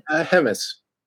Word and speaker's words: Hemmes: Hemmes.